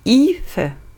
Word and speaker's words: Aoife: Ausgesprochen lautet, also der Vorname, Ife, so wird es ausgesprochen. Aoife geschrieben. Mal wieder was gelernt, ja, ist auch schön.